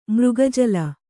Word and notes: ಮೃಗಜಲ: mřga jala

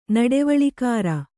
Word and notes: ನಡವಳಿಕಾರ: naḍavaḷikāra

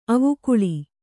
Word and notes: ಅವಿಕಲ: Kannada

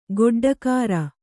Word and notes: ಗೊಡ್ಡಕಾರ: goḍḍakāra

